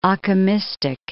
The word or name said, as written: Occamistic